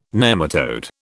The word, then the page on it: nematode: British English